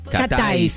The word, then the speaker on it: Cat Eyes: Catáis, se pronuncia. Yo le decía Catelles, como si fuera una. – Hoy estamos como queremos. – Bueno, yo toda la semana pasada Catelles. – Porque es cat eyes, como de los ojos de gato.